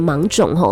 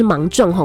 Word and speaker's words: 芒种: 好啦，今天呢是二十四节气当中的芒种啊、哦，有的人也会念成芒种吼，但是呢，我们跟循了这个教育部的发音，他是说是芒种吼，种稻的种。